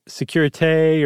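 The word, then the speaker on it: sécurité: but it's security and i don't know if you say it Securite or something like that, but it's spelled S C C U R I T E.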